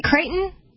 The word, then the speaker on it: Creighton: Am I saying that right, Creighton? – Yeah, it's Creighton, like Creighton University in Indiana, actually. Spelled that way, too.